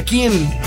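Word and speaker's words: Keane: La presencia del grupo Kane. Es King. King se pronuncia King.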